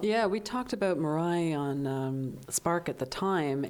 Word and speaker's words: Mirai: It's not that far off as that of Mirai. Mm-hmm. Is that the right way to say it? Yeah.